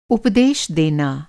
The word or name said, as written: उपदेश